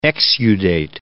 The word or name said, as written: exudate